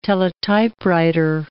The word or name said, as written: teletypewriter